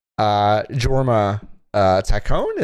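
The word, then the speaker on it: Jorma Taccone: Oh, it is like the first thing you see in the credits is, Jorma, Taccone.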